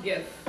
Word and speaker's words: GIF: It's pronounced GIF.